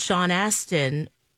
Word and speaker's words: Sean Astin: I remember Elijah Wood and Sean Astin.